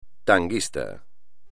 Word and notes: tanguista: espagnol

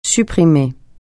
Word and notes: supprimer: French

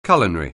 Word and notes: culinary: anglais

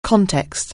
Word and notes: context: anglais

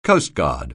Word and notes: coastguard: anglais

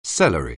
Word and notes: celery: anglais

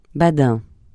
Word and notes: badin: francais

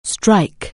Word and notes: strike: anglais

